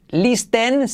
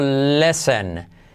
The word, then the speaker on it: listen: Empezamos con la número uno, la palabra escuchar, que no vas a decir listen, sino lesson. Lesson. Fijaos, lesson. Letra clave L, y como E, y no pronunciamos la T.